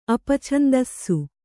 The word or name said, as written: ಅಪಛಂದಸ್ಸು